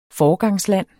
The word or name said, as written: foregangsland